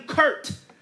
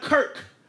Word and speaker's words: Kirk: Hell, the Redskins front office, his own front office called him Kurt instead of Kirk. They call him Kirk as K-U-R-T instead of calling him Kirk, K-I-R-K.